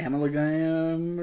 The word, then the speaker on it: amalgam: It's amalgam. – Amalgam, that's amalgam.